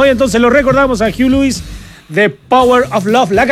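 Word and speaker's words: Huey Lewis: ¿cómo que se pronuncia? Para no equivocarme, don Miguel. Hugh Lewis, ¿ve? Y dice, Hugh and the News, claro.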